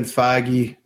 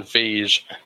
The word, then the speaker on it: Feige: I thought it was Feige. Kevin Feige. – Feige. – Because it's spelled like Siege, but with an F.